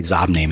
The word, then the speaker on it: Xobni: Oh, it's totally Zobni, man. – Well, yeah. – Zobni.